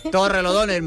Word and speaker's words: Torrelodones: Torrelodones. És així o què ho és? – Sí, ho és. Torrelodones, Madrid.